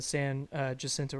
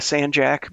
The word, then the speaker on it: San Jacinto: San Jacinto River. Yeah. Am I pronouncing that correctly? – Yeah. I mean, locals call it the San Jack, but yeah.